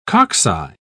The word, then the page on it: cocci: British English